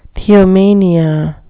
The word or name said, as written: theomania